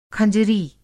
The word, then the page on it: खँजरी: Hindi